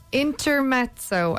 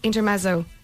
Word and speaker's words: Intermezzo: Yeah, Intermezzo. I think that's how you pronounce it, or is it Intermezzo? – Intermezzo. – Italian word, right?